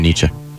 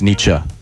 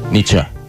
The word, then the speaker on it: Nietzsche: Yeah, actually, you know, Kenny Paul, heavily influenced by the works of Jung and Nietzsche. – Yeah, it's just great. – I hate to correct you on the pronunciation. It's Nietzsche. Nietzsche.